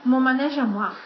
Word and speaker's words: Mon manège à moi: Titolo originale di questa canzone, la faccio, logicamente perché io lo pronuncio malissimo, Manège à moi,pronuncia bene tu. – Mon manège à moi.